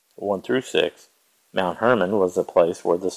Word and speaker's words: Hermon: In Hebrew, it's pronounced Hermon.